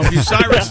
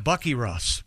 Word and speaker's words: Bucyrus: No, Bucyrus, some people trying to be funny, and I'm one of them, pronounce it Bucky Russ because it's oh. B-U-C-Y-R-U-S.